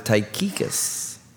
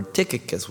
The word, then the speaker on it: Tychicus: Paul would be sending Onesimus back with Tychicus, or Tychicus, whichever you want to pronounce it, the bearers of the epistle to Colossians and Ephesians, Colossians 4, 7, and 9.